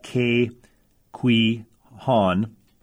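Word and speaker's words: Ke Huy Quan: I also think Kay Kwee Han,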